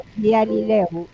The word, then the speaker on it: Bialylew: Beatriz Vialileu.